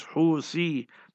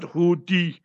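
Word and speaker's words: Houthi: Like they in Yemen, the term is Husi, not Huti.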